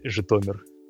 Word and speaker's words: Zhytomyr: It's pronounced Zhytomyr.